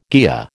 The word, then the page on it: kea: American English